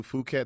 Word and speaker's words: Phuket: Well, that was a part we went to Phuket, Thailand, and I've had it there. So I know exactly what you're talking about. – I'm glad you correctly pronounced that city.